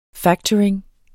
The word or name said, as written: factoring